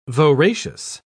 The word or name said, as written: voracious